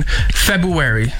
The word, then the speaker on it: February: But yeah, this person just was talking and casually through in February. It's not how you say it. – Say it again. – He said it. February. – February. – Yeah. – No, there's an R in there.